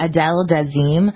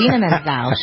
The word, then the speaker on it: Idina Menzel: That's how John Travolta mispronounced it. Adele Dazeem. Yep. It's Idina Menzel.